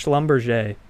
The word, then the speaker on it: Schlumberger: That's yeah, they're like, yeah, it's pronounced Schlumberger.